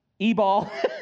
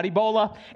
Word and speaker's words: Ebal: For heaven's sakes, Mount Ebal. Okay, Mount Gerizim in Deuteronomy. The children have to, these certain tribes of Israel stand Mount Gerizim, and these certain tribes of Israel stand on Mount Ebal, not Ebola.